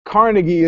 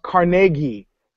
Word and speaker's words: Carnegie: And NPR has single-handedly tried to convince me that Carnegie is pronounced Carnegie.